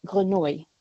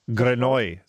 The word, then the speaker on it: Grenouille: Is it G-R-E-N-O-U-I? – U-I-double-L-E. – I think if it's the French word, it's Grenouille. – Grenouille, thank you. – Yeah. – Because it is the French word, but not pronounced very well.